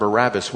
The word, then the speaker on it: Barabbas: With Barabbas, remember I told you that Barabbas is not the proper way to say his name.